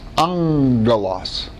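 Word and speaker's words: angelos (ἄγγελος): Anytime in Greek, two gammas come together, the first one in guttural is pronounced like an N. So the word angel is alpha gamma gamma. But when it's pronounced, it's angelos.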